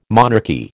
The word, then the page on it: monarchy: American English